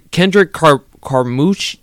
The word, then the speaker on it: Kendrick Carmucci: And his name is Kendrick, holy crap, Kendrick Carmucci. I'm sorry if I mispronounced his last name. But yeah, it sounds right to me.